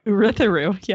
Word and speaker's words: Urithiru: Brandon says it like none of us just said it. Did you know that? He says Urithiru. – Oh. – With the U? He says the U.